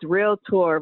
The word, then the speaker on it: realtor: It's realtor. We get real big on this. It's two syllables.